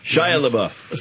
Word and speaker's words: Shia LaBeouf: But without that, I can never pronounce the Sia La LaBeouf. – Shia LaBeouf. – Sounds good. Shia LaBeouf.